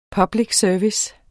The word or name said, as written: service